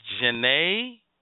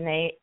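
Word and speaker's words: Jhené: Is it Janae? – Mm-hmm. – Is that how you say it? – Janae.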